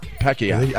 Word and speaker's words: Pacquiao: Right, I say it the way it's supposed to be said. All right. Pacquiao. – That's how they say it. Pacquiao.